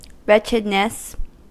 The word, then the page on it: misère: [mi.zɛʁ]; /mi.zeʁ/